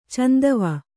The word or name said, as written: ಚಂದವ